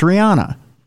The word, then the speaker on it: Rihanna: It's Rihanna. – Yeah. – But she pronounces it Rihanna.